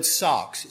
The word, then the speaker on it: sarx: It's this Greek word Paul uses, the word sarx. It's spelled S-A-R-X, sarx. Or sax, if you're from around here.